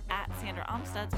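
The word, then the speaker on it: Sandra Amstutz: And you can find me online @SandraAmstutz.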